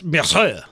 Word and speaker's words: Marsai: Her last name is Martin, but I'm not positive as to how her first name is pronounced. It could be Marcy. It could be Marseille. It could be Marsan. It's Marsai. – Marseille.